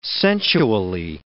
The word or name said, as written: sensually